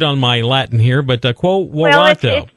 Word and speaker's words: Quo Warranto: You wrote an article the other day, Quo, is it pronounced Quo Warranto? – Yeah. – Quo Warranto.